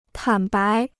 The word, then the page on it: 坦白: tǎn bái